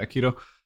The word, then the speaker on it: aikido: At a very young age, he saw an old man doing aikido. Is that how you pronounce it? – Yeah, aikido.